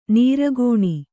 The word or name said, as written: ನಿರ್ಗುಣ